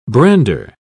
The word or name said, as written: brander